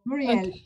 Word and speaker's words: Murielle: So Muriel. – Okay. Muriel, okay. – I pronounce it the French way, you pronounce it the Spanish way. There you go, Muriel.